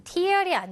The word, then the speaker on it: tear: tear 발음이 달라요. tear이 아니라 tear.